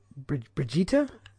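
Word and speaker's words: Brigitte: And so, Brigitta, if you're ever forgotten how to pronounce that, just think of Vegeta from Dragon Ball, and put a BR from it.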